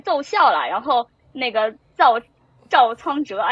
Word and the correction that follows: 赵昌哲: 哎呀，我这个发音真的对我来说好难呀。 – 赵昌哲，对，在车上的时候，然后他听到了听到了这个熟悉的歌声。